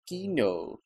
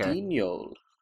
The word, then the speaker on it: Guignol: How do you pronounce Guignol? Are you getting that? – I'm getting a fan taking off. It sounds like a plane. – It's Guignol. – Okay. – Guignol.